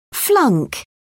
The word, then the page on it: flunk: British English